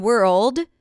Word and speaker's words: world: The difference between word and world is the dark L.